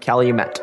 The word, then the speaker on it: Calumet: The village of Calumet. It is Calumet, right? It's not Calumet like it should be? – Yep, Met.